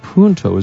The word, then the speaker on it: Punto: Adrian Gonzalez, Carl Crawford, Josh Beckett, and Nick Punt. I guess Punto is pronounced,